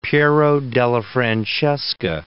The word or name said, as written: Piero della Francesca